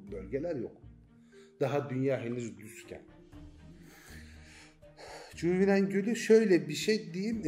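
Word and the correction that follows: Cuiviénen: Cüvinen, cüvi de şey demek ya da cüvi doğru değil tabii bütün C'ler K okunur ben ağız alışkanlığı öyle diyorum. Kuyvinen, kuyviye uyanış demek uyanış gölü demek cüvinen.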